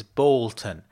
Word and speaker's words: Boulton: My first name is spelt F-R-A-N-C, and my surname is Bolton. That's the correct pronunciation, by the way. B-O-U-L-T-O-N.